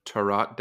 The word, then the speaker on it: tarot: When I found out Tilly Walden did a tarot deck, I was like, a tarot? Tarot. It's like a parrot or a carrot.